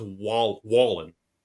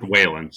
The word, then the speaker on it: Wallen: According to his Twitter account, it's pronounced Wallen. – Okay, well, that's dumb. – You going to tweet him that? – I'm not going to tweet him that, but I'm just saying it looks like it should be pronounced Whalen, so... – I believe it's Wallen.